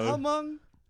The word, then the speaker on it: Hmong: Is it just Hmong? – Yeah, I think the H is silent. – Oh, that's because you guys. Hmong.